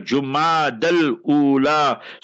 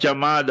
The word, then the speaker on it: Jumad al-Ula: First of all, learn to pronounce it. People send me invitation cards and the spelling is wrong there. J-U-M-A-D-A-L, Jumad al-Ula. So that is how you must spell it, 1445, not Jamada, that is incorrect.